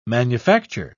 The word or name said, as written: manufacture